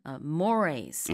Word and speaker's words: mores: 단어라서 순간적으로 mores 이렇게 발음을 했었는데 사전적으로 이제 발음 기호를 찾아보시면은 mores okay. – 이렇게 나와 있긴 합니다.